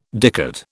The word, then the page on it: dicot: British English